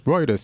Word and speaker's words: Reuters: Routers? Reuters? Reuters? – Reuters sounds right. – It is the right way to say it.